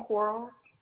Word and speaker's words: Quarles: Caitlin Quarles. – And could you spell that for me? – K-A-I-T-L-Y-N. Are you ready for the last name? – Yes, ma'am. – Q-U-A-R-L-E-S.